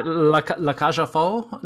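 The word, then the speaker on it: La Cage aux Folles: I'm assuming, I don't think the X is pronounced. – Yeah, I know. I was being a little silly. But, yeah, Le Cage aux Folles.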